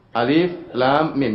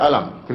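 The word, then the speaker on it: Alif Lam Mim: Alif, lam, mim. Jangan dibaca alam, keliru. Jangan dibaca alam, ya.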